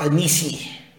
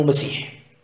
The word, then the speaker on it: مسيح: نعم، المسيح الدجال هو كلمة مسيح، وبعضهم نطق ميسيح بالكسر، وبعضهم قال ميسيخ، طبعا هذا بذكر بعض رواة الحديث، لكن أكثر على أنه مسيح، وقالوا لأنه ممسوح العين، ودجال من الدجل، ومبالغ في الكذب، فهو أول مبدأه يدعي النبوة ثم يدعي الألوهية.